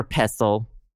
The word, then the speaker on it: pestle: Is it pestle or pestle? – Pestle. – Is the T silent? – I think the T is silent, yes.